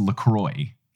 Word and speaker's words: LaCroix: The drink, according to their website, is pronounced LaCroix. The official website for that garbage seltzer water, which tastes like death, is said it's pronounced LaCroix, which is not correct in any language. Either go full French or go full English. Don't call it LaCroix. That's just wrong.